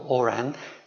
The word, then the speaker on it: Oran: One is the name of a town that's going to be mentioned, O-R-A-N, which I shall call Oran. In French, it might be Oran. And there's a gentleman mentioned in the text, a civil servant, whose name is G-R-A-N-D.